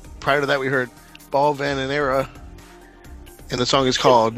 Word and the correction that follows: Balvanera: I like your pronunciation. What balls in my Venera? – Balvanera. – Balvanera. Yeah, I believe it's Balvanera.